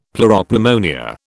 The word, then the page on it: pleuropneumonia: British English